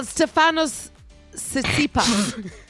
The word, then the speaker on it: Stefanos Tsitsipas: Well, Stefanos Tsitsipas. But if you see it in front of you, there's a T where there shouldn't be a T. – T-S-I-T-P-A-S or something, isn't it? – Yeah.